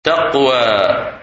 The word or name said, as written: Taqwa